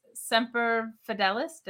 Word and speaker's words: Semper Fidelis: Matthew Plus is here saying that I know Alexis is a tremendous Marine simply because it's clear he takes Semper Fidelis.